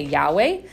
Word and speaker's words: Yahweh: It was, remember that Yod-Heh, Vav-Heh, which has kind of been lost in how it's meant to be pronounced, but many of us now say Yahweh.